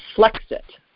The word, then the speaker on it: Flexit: The Economist joked that Florida and Texas should do something called, Flexit, like to rhyme with Brexit, but Flexit, like Florida and Texas should, secede from the union.